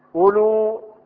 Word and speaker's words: أولو: وتزاد الواو في نحو أولو ها أولئك أولات أولو أولو، هل نحن ننطق واوا بين الهمزة واللام؟ ها نقول أولو ننطقها أولو؟ لا أولو، فتوجد الواو زائدة بين الهمزة والواو.